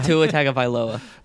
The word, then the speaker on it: Tua Tagovailoa: Yeah, Tua Tagovailoa.